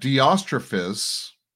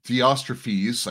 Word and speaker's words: Diotrephes: I wrote something to the church, but Diotrephes, Diotrephes, I think is probably the way it's pronounced, who loves to be the first among them, does not accept what we say.